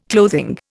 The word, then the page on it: clothing: Indian